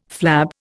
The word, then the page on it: flab: Indian